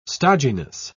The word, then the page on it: stodginess: British English